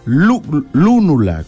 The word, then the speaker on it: lúnula: Lúnula, con un acento en la U.